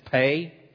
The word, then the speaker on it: Pe: The next one is Pei. We spell it P-E.